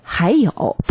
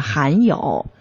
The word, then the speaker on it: 还有: 发这个还音的时候，很多人，嗯，我们在平时你读一些东西的时候，可能会含有，还有，咱们要记清楚了，这个还和含是不一样的啊，还有不是含有，咱们在发音过程中一定要注意，在我们经常听听到有一些人在说话的时候，哎，还有还有，不是含有，是还有还有还有，好，记住这个 h i 还，好，咱们继续学习双音节。